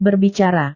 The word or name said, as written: berbicara